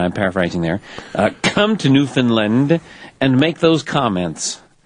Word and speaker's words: Newfoundland: It rhymes with understand. Newfoundland. – Newfoundland.